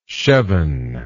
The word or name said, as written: chevon